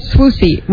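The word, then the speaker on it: Swoosie: It's Swoozy. Rhymes with Lucy.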